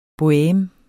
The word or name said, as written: boheme